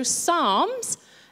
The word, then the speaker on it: Psalms: So Psalms is the book of the Bible, really mean that it's a silent P.